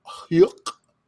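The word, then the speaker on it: Huyck: So, George Lucas, the people who co-wrote American Graffiti with him in 1973, his film school cohorts, Willard Hyuk. How to pronounce it? It sounds like when Goofy laughs. That's what it looks like. It sounds like H. Uyck. It looks like Hyuk.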